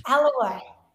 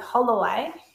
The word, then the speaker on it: Alloway: Yes, good. Not Holloway. – I can say it. I just like to stir you up with Holloway. – No, it's because I'm triggered because my entire life people have got my last name wrong and it's always like, Alloway. No. Is it Holloway? No. Is it Alloway? No, it's just Alloway.